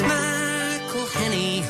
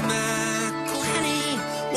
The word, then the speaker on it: McElhenney: It's McElhenney, McElhenney. While ways to massacre and mispronounce it, there are many. – McElhenney. – It's McElhenney. If there was doubt, now there won't be any. It's McElhenney. First it's Mackle like a tackle when we take him to the ground.